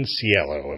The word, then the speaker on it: Cielo: And welcome back to Radio Wasteland, and we are here with our guest, Dr. Lauren Cielo.